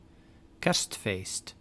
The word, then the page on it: Christmas: [ˈkɹɪs.məs]